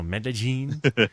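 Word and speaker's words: Medellín: Medellin or Medellin? – No, you said it right. You said it right. – Oh, Medellin.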